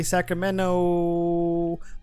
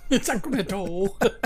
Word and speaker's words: Sacramento: And then we have to the state next to me, California, more specifically Sacramento. Wait, I said that wrong. – Sacramento.